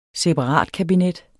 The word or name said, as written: separatkabinet